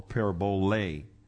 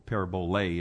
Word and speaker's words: parabole: or parabole. Parabole is really the way you'd say it. It's the L-E at the end is a long E. It's pronounced like A-Y, so it's parabole.